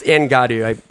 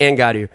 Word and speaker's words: Angadu: Sorry, it's Angadu. Sorry, I keep mispronouncing it. It's Angadu. But basically, it's a large Chewbacca-like creature.